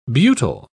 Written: butyl

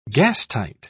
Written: gastight

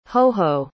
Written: hoho

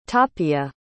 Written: tapia

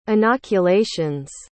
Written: inoculations

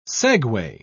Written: segue

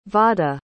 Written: vada

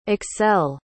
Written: accel

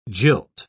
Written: jilt

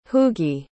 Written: hugi

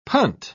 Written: punt

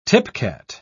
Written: tipcat

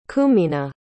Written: cumina

